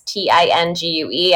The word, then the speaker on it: Tingue: That's TINGUE. I have a very strange Welsh last name that does not, it's not spelled the way it's pronounced, but don't worry about it.